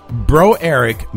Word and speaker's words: Broerick: Unfortunately for Bo Eric, Bro Eric. How would you pronounce that? Would you pronounce that Bro Eric? Thank you. B-R-O-E-R-I-C-K. Bro Eric.